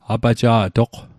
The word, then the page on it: apaja'toq: Mi'gmaq